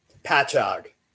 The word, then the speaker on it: Patchogue: Patchogue.